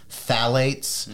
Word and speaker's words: phthalates: They're called phthalates. Mm-hmm. It's not spelled like that, though. It's spelled like PHTH something F.